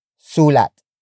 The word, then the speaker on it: sulat: And that is sulat. Sulat. Sulat. Sulat is spelled as S-U-L-A-T.